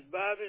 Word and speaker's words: Bobby: First name is Bobby and my last name is Gam.